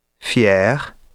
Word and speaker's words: fier: Keep in mind as well that final ER is sometimes pronounced like French R, but often pronounced like E. Fier, but chanter, danser, parler.